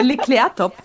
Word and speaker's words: Leclerc: Also, Beate, ich, ich, 10 Euro haue ich in unseren Eclair-Topf. Äh, Leclerc-Topf, jetzt habe ich es auch noch richtig ausgesprochen.